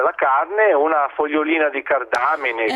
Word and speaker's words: cardamine: È vero, perché i cavoli e la loro famiglia, anche queste cardamine? – Okay. Sì, cardamine, sì. – Non ho sbagliato l'accento. Cardamine, ma quanto sono brava!